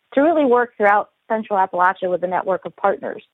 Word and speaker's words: Appalachia: Yeah, so I guess it depends on where you're from in Appalachia, but when I first moved down here, I was corrected quite often that it's Appalachia.